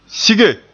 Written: sige

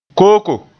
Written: kooko

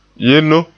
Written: yenno

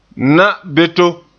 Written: na'anbeto

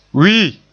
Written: wii

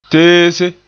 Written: teese

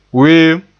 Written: weem

